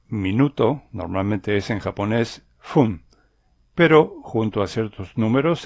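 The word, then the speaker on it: fun (ふん): Básicamente, minuto se dice fun, pero en algunos casos el sonido de la F en fun cambia a P y queda pun.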